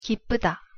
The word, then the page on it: 기쁘다: Korean